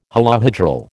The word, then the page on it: holohedral: American English